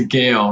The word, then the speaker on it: Gael: It's a Gael, Roxbury Gales. – Oh, it's a Gael. – You have to at least say it right.